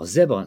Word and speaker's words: Z#BRE: Alors Zebre, c'est une société intéressante déjà dans son nom puisque ça s'écrit Z hashtag BRE, mais ça se prononce bien Zebre.